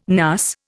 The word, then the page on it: NAS: Indian